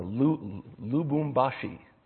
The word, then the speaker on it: Lubumbashi: It's uh, Lubumbashi.